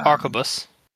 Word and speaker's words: arquebus: I'm gonna butcher this pronunciation, but I think it's arquebus. – Yeah, arquebus.